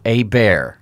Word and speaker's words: Hebert: It's by Andrew Hebert, and that last name, the last names are hard.